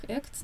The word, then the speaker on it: ACT: Я буду называть по привычке его акт, Acceptance and Commitment Therapy, аббревиатура по-английски читается как ACT, но в русскоязычном сообществе чаще всего уже все по-свойски называют его актом.